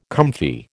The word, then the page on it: comfy: American English